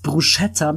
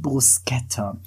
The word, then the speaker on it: Bruschetta: Und im Italienischen, wenn da ein CHI spricht, dann wird das hart ausgesprochen. Ach, du sagst Bruschetta, das heißt doch eigentlich Bruschetta.